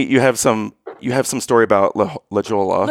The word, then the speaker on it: La Jolla: Has something to do with La Jolla. My husband pronounces it La Jolla or whatever. – Oh, totally calling you out. – Yeah, but it is La Jolla.